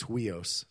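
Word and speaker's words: huios (υἱός): Son there is weos. No, it's huios. Sorry, I phonetically wrote it down. It's spelled with an H. H-U-I-O-S. Huios.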